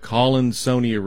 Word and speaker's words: Collinsonia: It's like the word Colin, and then almost like Sonia. – Well, that would make sense. – That doesn't even make sense the way I said it. Yes, I'm so brilliant in my phonic pronunciations. So it's called Collinsonia root.